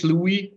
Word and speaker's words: Louis: Actually, it's Louis. It's French. – Louis. – But okay. When I was in the US, I started to just accept Louis because Americans have a really hard time pronouncing a French name.